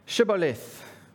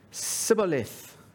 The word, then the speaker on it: Shibboleth: And people from Ephraim couldn't make the sh sound that they should have. And instead of saying Shibboleth, they said Sibboleth.